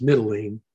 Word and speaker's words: Mytilene: So it says that he met us at Assos and took him aboard and went on to, looks like Mytilene, but it's Mytilene, the way you pronounce it.